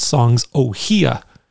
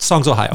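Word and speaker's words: Songs: Ohia: Anyway, someone picked a Magnolia Electric Company album and they talked about Songs Ohio, except he pronounced it Songs Ohia. Yeah. And his bandmate looked at him and went, wait, what did you just say? And he's like, yeah, I know. Everyone says that when I say it that way, but that's because Ohia is like a flower, and that's how you pronounce it. And every single person pronounces it wrong.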